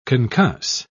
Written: concuss

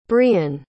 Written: brion

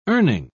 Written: earning

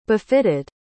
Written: befitted

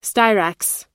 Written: styrax

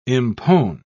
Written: impone

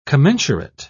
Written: commensurate

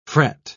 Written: fret

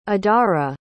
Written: adara

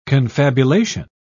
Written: confabulation

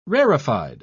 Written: rarefied

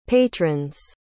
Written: patrons

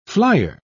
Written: flier

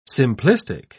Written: simplistic

